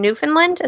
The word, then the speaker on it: Newfoundland: The second one was Dead Towns in Canada, in Newfoundland. Is that how you say it? – Yep. – And yeah, so then. – Like the dog.